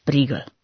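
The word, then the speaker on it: Prýgl: Hantecové pojmenování brněnské přehrady patrně pochází z německého slova der Prügel, které znamená klacek, kij nebo obušek, a vyslovuje se tvrdě a spíše dlouze Prígl.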